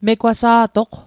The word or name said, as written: megwasa'toq